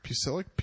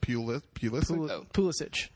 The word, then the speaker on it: Pulisic: how do you say this name again? I say it wrong every time. Pusilic? – Pulisic.